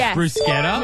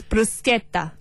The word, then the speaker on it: bruschetta: I want to say bruschetta, but I think it's bruschetta. – Correct. – Okay, so it's bruschetta.